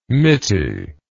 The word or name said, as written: mitty